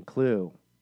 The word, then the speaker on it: Cluedo: He said, if you are a character in Cluedo for those overseas. – Cluedo. – Cluedo. – You don't pronounce the E, it's a silent E.